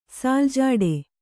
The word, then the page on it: ಸಾಲ್‍ಝಾಡ: sāl jhāḍa